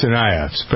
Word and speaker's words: Tsarnaev: Tsarnaevs. But how do you forget that out of TSA Tsarnaev, I guess. Tsarnaev. – The T is silent.